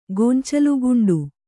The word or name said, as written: ಗೊಂಚಲುಗುಂಡು